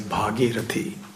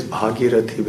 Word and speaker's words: Bhagirathi: And because she came in response to King Bhagirath's request, on the earth she is known as Bhagirathi, the long A, the first A. The king is Bhagirath, short A, and the river is Bhagirathi with the long A.